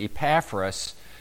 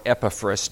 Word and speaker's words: Epaphras: One theory is that Paul's friend and co-missionary Epaphras, or Epaphras, depending on your pronunciation, is the one that actually founded that church in Colossae.